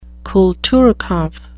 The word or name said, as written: Kulturkampf